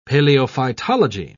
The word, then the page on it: paleophytology: British English